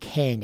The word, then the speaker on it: Kain: This guy called Kane, it's spelled K-A-I-N.